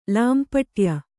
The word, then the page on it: ಲಾಂಪಟ್ಯ: lāmpaṭya